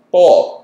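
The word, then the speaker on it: Boal: It's spelled like coal with a B. – Just say Bull. – Bull.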